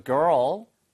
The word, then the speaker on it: girl: 이 발음은 자주 해드리겠지만 걸, G-U-L-L, girl, seagull, seagull 할때 우리가 갈매기라고 할때 그거는 girl 이거고요. 이거는 girl, girl 한 다음에 earl 처음 뒤로 땡기면 돼요.